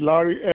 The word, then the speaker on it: Larry: First name is Larry, L-A-R-R-Y.